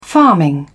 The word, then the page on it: farming: British English